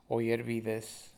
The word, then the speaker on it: Oyervides: Juan, J-U-A-N, Oyervides, O-Y-E-R-V-I-D-E-S.